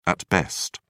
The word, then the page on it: at best: British English